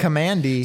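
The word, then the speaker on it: Kamandi: Commandy.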